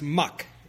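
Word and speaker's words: Muck (麥): His surname was pronounced Muck in Cantonese.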